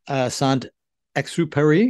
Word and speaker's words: Saint-Exupéry: And the first one is from your biography of, Saint-Exupéry. Did I get the accent on the right place? – Yep.